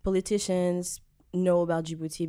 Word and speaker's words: Djibouti: Djibouti.